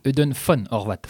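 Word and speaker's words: Ödön von Horváth: Est-ce que je l'ai bien prononcé ? – Pas mal. Eudon von Horvath.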